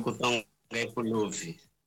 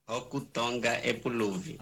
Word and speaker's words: Okutonga Epuluvi: Okutonga Epuluvi. – Como é que se pronuncia? – Okutonga Epuluvi, em língua nacional umbunda.